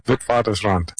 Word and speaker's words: Witwatersrand: How do you say it again? – Witwatersrand. – Yeah, because you speak a little Afrikaans. For me, it's Witwatersrand. I